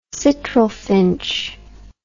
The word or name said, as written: citril finch